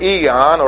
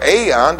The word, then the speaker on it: aeon: The other is the word eon or aeon, depending on how you say it.